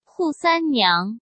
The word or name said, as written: Hu Sanniang